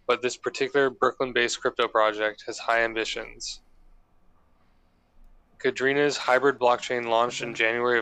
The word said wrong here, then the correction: Kadena: Cadena. – Kadena's hybrid blockchain launched January of last year.